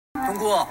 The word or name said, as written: Dong guo